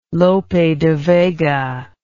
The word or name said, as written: Lope de Vega